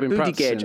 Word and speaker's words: Buttigieg: I think, you know, what's been labelled at the Biden administration and Buttigieg as well is that, you know... – Oh, well said. You actually said his name right. Buttigieg.